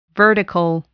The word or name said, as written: vertical